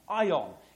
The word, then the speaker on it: aion: It's spelled A-I-O-N, ion. It's the word we get the English word eon.